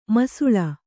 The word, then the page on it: ಮಾಸಾಳು: kannada